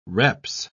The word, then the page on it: reps: British English